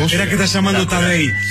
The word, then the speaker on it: Tadej: Tadej, como Tadeo, pero Tadej Pogačar se escribe.